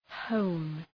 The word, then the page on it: hone: həʋn